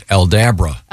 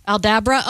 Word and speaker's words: Aldabra: I was thinking it was Aldabra. – Aldabra? Oh, like Abracadabra. – That'd be cool because yeah. – it would rhyme with abracadabra.